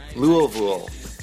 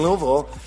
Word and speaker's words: Louisville: I'm going to be in somebody told me how to say this. They both rhyme with rule. Louisville. I'm going to be in Louisville.